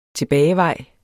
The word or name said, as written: tilbagevej